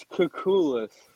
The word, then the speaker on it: Kukulis: It's Kukulis, like cuckoo. – Like cuckoo. – Like couscous, like the little pasta.